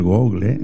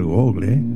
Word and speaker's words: Google: En el Google, en el Google. Se dice Google, pero yo digo Google porque se escribe Google, ¿no?